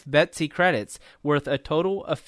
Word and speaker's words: BETC: It's often referred to by its acronym BETC, pronounced Betsy.